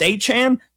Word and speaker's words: Achane: Achane, how do we say his name? We don't know. Nobody knows. Oh, it's Achane.